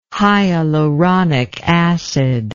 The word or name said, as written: hyaluronic acid